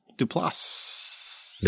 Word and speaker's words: Duplass: He's not French, so you pronounce the S. – Okay. – There's two of them. It's S's. Duplass.